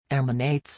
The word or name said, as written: emanates